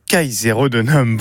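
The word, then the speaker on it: Caesarodunum: Michael Grenat, vous êtes directeur artistique de Kaiserodenum. Bonjour. – Bonjour. – Est-ce que je l'ai bien prononcé ? – Ah mais oui, tout à fait, parfaitement.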